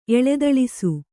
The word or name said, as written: ಎಳದೊಳಸಿ